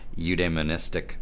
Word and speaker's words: eudaimonistic: You use a phrase, you're probably not going to pronounce this right, but eudaimonistic human flourishing. Did I say that right? – More or less, yep.